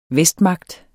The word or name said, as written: vestmagt